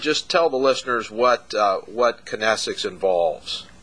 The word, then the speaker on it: kinesics: And Catherine Dance is an expert in, is it kinesics or kinesics? – It can be pronounced either way. I say kinesics, but that's probably my Midwest upbringing.